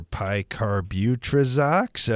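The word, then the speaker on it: picarbutrazox: I hope I said that right. A lot of help on some of those seed and seedling diseases, too. – Correct. And you did. You did a very good pronunciation of Picarbutrizox.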